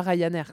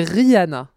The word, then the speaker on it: Rihanna: C'est très rare, mais parfois j'entends des gens dire Rihanna et en fait, c'est pas Ryanair, quoi. C'est Rihanna. Si, j'ai entendu des gens le dire et ça m'énerve.